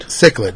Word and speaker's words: cichlid: Cichlid? – Cichlid, yeah, mm-hmm.